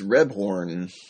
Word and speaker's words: Rebhorn: First of all, I love the use of, for the life of me, I don't know how to pronounce this actor's name, but it is James Rebhorn. Is that how you say it? Was it Rebhorn? – Or? I think it's Rebhorn.